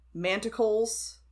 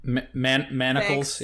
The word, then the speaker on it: manacles: Did the Dufresnes have, like, manticles, like, around anywhere? – What are manticles? – Like, shackles? Like, manacles? Did I say it wrong? – Manacles?